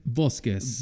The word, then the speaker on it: Bosquez: Bosquez.